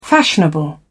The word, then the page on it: fashionable: British English